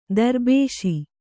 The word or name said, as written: ದರ್ಬೇಶಿ